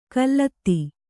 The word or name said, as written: ಖಲತಿ